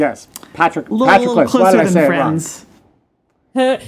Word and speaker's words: Patroclus: him who Patroclus had foredoomed to slay in Troy's rich soil, far from his native land. – You mean Patroclus? – Yep, I totally said it wrong.